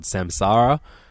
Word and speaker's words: Samsara: He commits suicide, and thus the band, who are called Samsara, which even rhymes with Nirvana, mm-hmm.